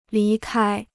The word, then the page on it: 离开: lí kāi